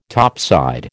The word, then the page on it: topside: American English